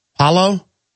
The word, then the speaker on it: Paolo: Let's take a call from, how do you pronounce P-A-O-L-O? Paolo?